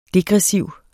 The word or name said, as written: DEGRESSIVE